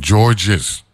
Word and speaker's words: George: In 1998, you had a storm named Georges, but they pronounce it George. Right? It's like a French thing.